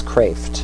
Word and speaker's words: Kreeft: His last name is spelled K-R-E-E-F-T. Yeah, K-R-E-E-F-T. So it looks like Kraft, but it's Kraft is how you pronounce it.